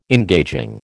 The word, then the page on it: engaging: American English